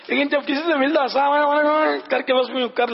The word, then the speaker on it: السلام علیکم: السلام علیکم میم پر پیش بھی پڑھنا ہے، السلام علیکم ورحمۃ اللہ وبرکاتہ یا وبرکاتہ.